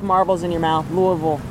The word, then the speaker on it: Louisville: I still struggle because Colorado is Louisville. – Yeah. – And then here it's Louisville. I'm like, okay, gotta differentiate the two.